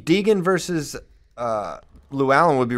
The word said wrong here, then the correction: Deegan: But Deggan, I believe, as it's pronounced, not Deegan, as I mispronounced it earlier.